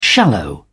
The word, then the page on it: shallow: British English